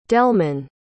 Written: delmon

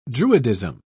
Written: druidism

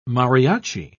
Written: mariachi

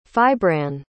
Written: fibranne

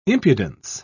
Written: impudence

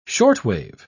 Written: shortwave

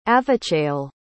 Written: avichayil

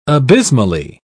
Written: abysmally

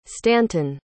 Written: stanton

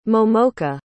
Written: momoka

